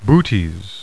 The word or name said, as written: Butes